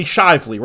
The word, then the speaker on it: Shively: It's Lively Shively, right, Kevin? Am I saying that right? – It sure is.